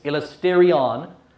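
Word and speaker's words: elasterion (ἱλαστήριον): It's spelt with an I, not an H. It's elasterion. Elasterion.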